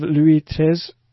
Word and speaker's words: Louis XIII: We look forward to seeing more of Louis XIII. How bad am I butchering it? – No, that's good. A lot of progress in the beginning. – Louis XIII.